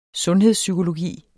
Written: sundhedspsykologi

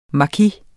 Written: maki

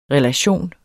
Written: relation

